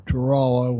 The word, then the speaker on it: Tarawa: A place called Tarawa, or Tarawa, the way we pronounce it,